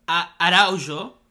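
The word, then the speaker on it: Araújo: Luis Araujo, right? It's Araujo. Yeah. It's not Araujo. I have to train myself not to say Araujo because it's a Brazilian player, Portuguese pronunciation.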